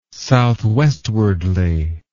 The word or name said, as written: southwestwardly